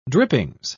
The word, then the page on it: drippings: British English